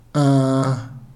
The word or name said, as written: aha